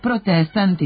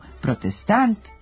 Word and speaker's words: protestant: Akcenat u srpskom jeziku ne može da stoji na poslednjem slogu u reči, tako da se u rečima stranog porekla sa završetkom na ant, ont i ent mesto akcenta pomera za jedan slog unapred i od kratkosilaznog postaje kratkouzlazni, a od protestanta i demonstranta postaju protestant i demonstrant.